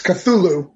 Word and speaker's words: Cthulhu: It's Cthulhu is how I pronounce it.